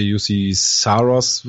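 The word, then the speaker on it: Juuse Saros: Ich glaube, bei den Predators wird viel darauf ankommen, wie Pekarinen beziehungsweise Jussi Saros wird da, glaube ich, ausgesprochen,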